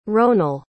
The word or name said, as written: ronal